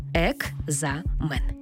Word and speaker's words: екзамен: Слово «екзамен» пишемо з літерою «К». «Екзамен». А як ми його вимовляємо? «Екзамен». Наче чується звук «г». Чому так відбувається? Тому що на початку в цьому слові стоїть глухий звук «к», а далі дзвінкий «з». А ми вже з вами проговорили раніше, що глухий звук поруч із дзвінким шукає собі дзвінку пару. Тобто глухий плюс дзвінкий перетворюється на дзвінкий плюс дзвінкий. Тому слово написано через «к» і «з». А в транскрипції і у вимові будемо чути «г» і «з».